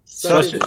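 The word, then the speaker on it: Suches: How do you pronounce that again? – Sutches, Georgia. – Sutches, Georgia. Right on.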